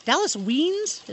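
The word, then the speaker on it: Dallas Wiens: So Dallas Weens, is that how we pronounce his name? – Sure.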